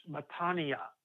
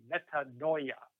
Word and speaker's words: metanoia: It's metanoia, or if you were to pronounce it a little differently in English, it would be metanoia.